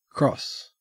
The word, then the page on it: cross: Australian English